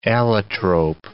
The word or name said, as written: allotrope